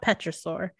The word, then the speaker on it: pterosaur: That was my weirdness. Pterosaur. Sorry, I just have to pronounce every letter in a word. – I mean, it doesn't make sense for there to be an invisible P, so.